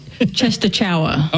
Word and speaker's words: Częstochowa: It looks like Częstochowa.